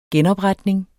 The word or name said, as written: genopretning